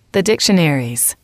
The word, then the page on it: the dictionaries: female